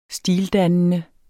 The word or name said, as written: stildannende